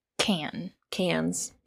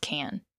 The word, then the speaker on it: Cannes: Cannes Film Festival? – Cannes. No, sir. Just Cannes. Yes. – Cannes Film Festival. – The S isn't pronounced.